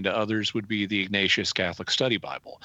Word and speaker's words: Ignatius: Ignatius.